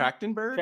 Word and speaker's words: Trachtenberg: How do you say her last name? Trachtenberg. Trachtenberg. Trachtenberg. Trachtenberg. Come on, put some respect on her name, yeah.